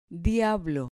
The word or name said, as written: diablo